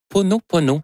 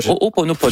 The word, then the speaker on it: Ho'oponopono: Non, c'est une philosophie hawaïenne de, je vais le prononcer comme il faut, de l'O-O. Ho'oponopono, le Ho'oponopono,